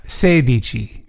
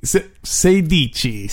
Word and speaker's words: sedici: Sedici. – Sedici.